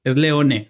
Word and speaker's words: Leone: Sergio León, es otro director que hizo películas en las que ha hecho Tarantino. No, es que no es Lion, es Leone, se escribe Leone, así que...